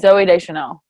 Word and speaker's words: Zoe Deschanel: Zoe Deschanel.